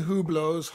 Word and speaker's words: Hublot: Actually, of the three new Hublots, Hublots or whatever, I mean, this is the one, Hublots, yeah. And the H is silent. – I know. Hublot.